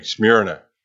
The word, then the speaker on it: Smyrna: I think that's the proper way, at least that's how my computer tells me, the proper way to pronounce this thing, Smyrna.